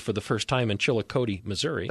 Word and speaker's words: Chillicothe: That's Chillicothe. See, it's Chillicothe, I think, is the way it's pronounced in Missouri.